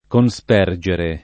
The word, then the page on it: conspergere: Italian